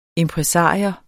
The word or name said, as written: IMPRESARIOEN